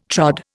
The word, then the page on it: trod: Indian